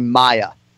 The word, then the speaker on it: Maia: Hey, give me the two syllables. Just my. Let me hear you say Maya.